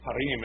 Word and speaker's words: harem: harem, or harem is the way you pronounce it, if you're doing it in Arabic or close to Hebrew, and, you know, make her one of his wives.